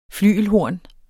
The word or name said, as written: flygelhorn